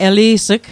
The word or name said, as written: eli's'g